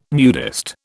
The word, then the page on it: nudist: British English